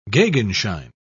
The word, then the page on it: gegenschein: British English